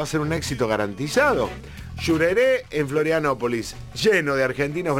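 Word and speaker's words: Jurerê: Porque ayer se conoció, el 13 de enero, en Florianópolis, no sé cómo se pronuncia, ¿es Jureré o Jureré? – No, Jureré. – Jureré.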